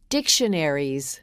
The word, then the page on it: dictionaries: female